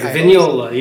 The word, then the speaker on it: Vignola: Vignola? Am I pronouncing it correctly? – That's correct. Vignola, yep.